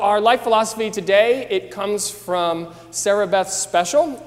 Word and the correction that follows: Speciale: or as she told me the other day, it's actually pronounced, if you're Italian, speciale, which I really love. And so I didn't know, she has an E on the end of her name, and so I wasn't exactly sure.